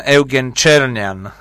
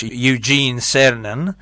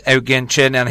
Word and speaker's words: Eugen Cernan: The last man on the moon, Eugen Cernan, or how you pronounce it in English, Eugene Cernan, is Eugen Cernan. He was Slovak, and he was back to Slovak Republic, and he got big award, and he did some meetings with the public.